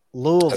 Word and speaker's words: Louisville: The 106th PGA Championship will be in Louisville. Did I pronounce that correctly? Louisville. – As far as, far as I'm concerned, you did. Like, Louisville.